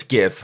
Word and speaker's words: GIF: It's GIF. Hard G starts the word. It's G. How do you pronounce golf? Is it Jolf? No, it's golf.